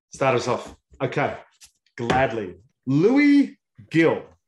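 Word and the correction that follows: Gil: Heel. – Why did it say Gill? – Well, some things are pronounced differently than the way they're spelled.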